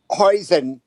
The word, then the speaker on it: hoisin: How is it pronounced? Do you know? – Hoisin. – It's hoisin. – Hoisin.